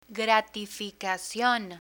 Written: gratificacion

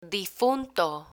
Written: difunto

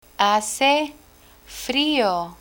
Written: hace frio

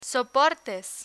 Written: soportes

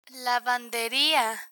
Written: lavanderia

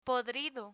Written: podrido

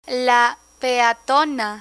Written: la peatona

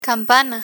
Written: campana

